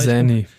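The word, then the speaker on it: Xanny: Wie, wie spricht man die Droge richtig aus? – Guck mal, Zanny. Zanny. Zanny.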